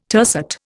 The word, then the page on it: tercet: Indian